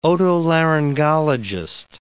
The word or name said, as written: otolaryngologist